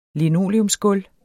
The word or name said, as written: linoleumsgulv